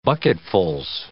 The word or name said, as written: bucketfuls